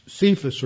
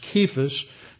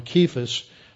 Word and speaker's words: Cephas: And that he was seen by Cephas, or actually it should be pronounced Kephas, because in Greek there was no uh, soft, uh, soft C, so it's a K that is actually in the original, so it should be translated with a hard uh, guttural sound, Kephas, then by the 12.